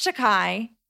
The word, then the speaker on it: Takei: It had George Takei. Takei? Takei. And it was... – Takei? Takei. – This is... Takei? – How do you spell it? – T-A-K-E-I? – Takei. – If it's Japanese, then it's Takei.